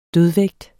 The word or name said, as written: dødvægt